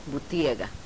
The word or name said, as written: butega